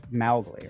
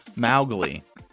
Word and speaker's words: Mowgli: Kipling also meant for the first syllable to rhyme with cow. So we've been saying his name wrong this whole time. Oh. It should be Cowgli. – Well, Mowgli, right? – Oh, yes.